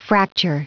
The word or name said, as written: fracture